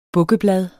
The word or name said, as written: bukkeblad